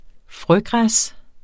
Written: frøgræs

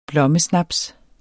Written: blommesnaps

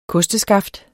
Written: kosteskaft

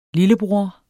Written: lillebror

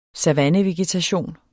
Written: savannevegetation

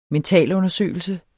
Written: mentalundersøgelse

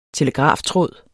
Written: telegraftråd

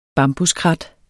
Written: bambuskrat

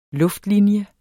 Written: LUFTLINJE

